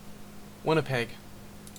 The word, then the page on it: Winnipeg: /ˈwɪnəˈpɛɡ/